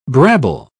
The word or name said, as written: brabble